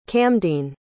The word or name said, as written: camdene